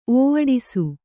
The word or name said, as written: ಓವಡಿಸು